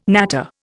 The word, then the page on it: natter: Indian